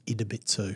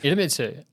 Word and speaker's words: Idemitsu: I actually got sent a clarification on that. It's Idemitsu. – Idemitsu.